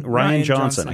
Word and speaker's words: Rian Johnson: Ryan Johnson. I keep wanting to say Rian Johnson because it's spelled R-I-A-N.